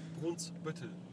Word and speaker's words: Brunsbüttel: Brunsbüttel.